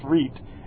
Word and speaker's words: REIT: That's what that REIT, how it's pronounced REIT, and that's what it means.